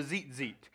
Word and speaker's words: tzitzit: T-Z-I-T-Z-I-T. Tzitzit. I don't understand Hebrew, and I don't ever claim to, but that's how it's, Mike told me that's how it's pronounced, because I asked him. You know, I redneck everything. I'm like, tzitzit. You know, like, but Mike informed me it is pronounced. He's been to college for these things. That it is tzitzit.